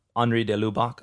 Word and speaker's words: Henri de Lubac: But the isolation of this, so he quotes Henri de Lubac.